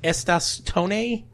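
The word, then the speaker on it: Estas Tonne: And I think his name is pronounced Estas Tone. Or, I don't know. It's... My Latino friends will know, like, ¿cómo estás? So his first name is Estas, E-S-T-A-S, and his last name is T-O-N-N-E.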